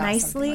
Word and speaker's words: Knisley: It's called Go to Sleep, I Miss You, Cartoons from the Fog of New Parenthood by Lucy, oh gosh, Neasley? Is it a silent K? – I think it's N? Nicely. Yeah, Nicely.